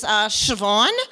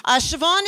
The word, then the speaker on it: Siobhan: As Lizzie said, my name is Siobhan. And I was that kid with the funny hair and the funny name. Siobhan is an Irish name with lots of consonants and a silent B.